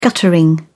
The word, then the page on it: guttering: British English